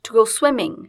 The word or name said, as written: to go swimming